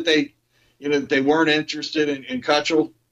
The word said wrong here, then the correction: Keuchel: isn't that how you pronounce his name? – Keuchel. – Keuchel.